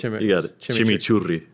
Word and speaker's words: chimichurri: Put out, you know, like you were talking about your chimichurri, and this is something that, and I know I'm not pronouncing the R's right. – You got it. Chimichurri.